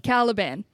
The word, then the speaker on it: Caliban: Caliban.